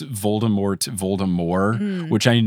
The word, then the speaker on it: Voldemort: So as far as I'm concerned, the conventional way to say it is Voldemort, and if you don't pronounce the T, you are trying too hard, and I will judge you.